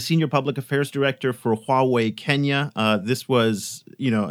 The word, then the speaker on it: Huawei: So just for the record, the way you pronounce it is Huawei, you know, two characters, Hua and Wei.